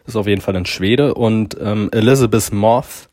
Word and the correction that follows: Moss: keine Motte, Elizabeth Moss,